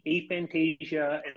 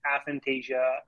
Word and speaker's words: aphantasia: So for the record, it's probably somewhere between aphantasia and aphantasia. It's more like a tomato in terms of the pronunciation on that.